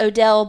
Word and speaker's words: Odle: Now it's spelled O-D-L-E. A couple of places I heard it pronounced Odell, but like by and large, Odle.